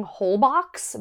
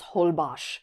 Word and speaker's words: Holbox: I was saying Holbox because that's how it's spelled. And they're like, oh, it's actually a Mayan word. And in Mayan, the X is pronounced like an SH. So it's Holbox, which like I knew that an X is an SH.